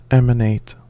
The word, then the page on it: emanate: American English